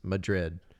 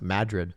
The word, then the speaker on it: Madrid: And he looks and sees at the Mirage, the billboard that says Madrid. – 1 mile. – Which they pronounce Madrid. – Madrid. – It's Madrid. It's not Madrid. It's Madrid. – Yeah, the American pronunciation. Madrid.